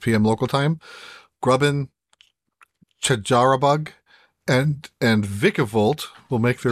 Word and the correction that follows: Charjabug: I know this is your Pokemon, but did you just mispronounce the name of a Pokemon? – I don't think I ever said that. Oh, you did. Out loud before. You did. It's Charjabug. – Charjabug.